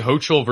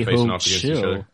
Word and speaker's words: Hochul: I was Googling a pronunciation guide as we speak here. No, it's Kathy Hochul versus Malcolm Nance. And Kathy's up first. Two women. – Well, it's, she's certainly not Kathy Hope Schill.